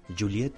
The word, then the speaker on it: Joliet: ¿Cómo se, se pronuncia Joliet? Juliet, Juliet. Juliet, bueno, no es francés, bueno, eh, Joliet, está en Joliet, en, Illinois, en la bella y soleada de Illinois.